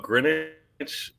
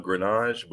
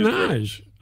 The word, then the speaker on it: Greenwich: It's actually, I grew up Greenwich, but I chose the French pronunciation of Greenage, but I actually used Greenage.